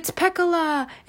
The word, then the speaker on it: Pecola: Piccola? – I thought you were gonna say Petunia. – No, I'm like not saying it. I'm not pronouncing it right, but it's Pecola.